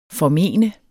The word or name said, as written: formene